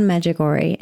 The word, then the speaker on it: Medjugorje: And it's called Medjugorje.